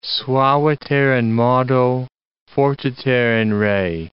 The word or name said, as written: suaviter in modo, fortiter in re